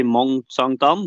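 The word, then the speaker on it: Mont-Sainte-Anne: I Mont saint Anne, det sa jeg sikkert helt feil. Klarer du å uttale det bedre, eller? – Nei, jeg tror det stemmer ganske bra, jeg.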